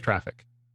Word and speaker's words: Traefik: They say it is pronounced Traffic. The pronunciation guide. – T-R-A-E-F-I-K.